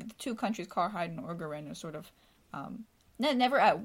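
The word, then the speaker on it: Orgoreyn: It's like Orgorin. How do they say it? – Orgorin. – Orgorin. So it's spelled really weird.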